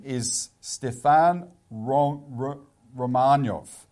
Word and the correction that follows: Romaniw: Romaniev. – Stefan Romaniev, R-O-M-A-N-I-W, who is the co-chair of the organisation of, Federation of Ukrainian Organisations.